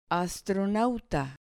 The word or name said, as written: astronauta